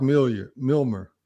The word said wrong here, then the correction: Millar: or whatever. I'm saying it wrong. I might be saying it wrong. – Mark Millar, I think is his name.